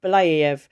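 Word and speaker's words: Belyaev: I can never say his name. – Belayev.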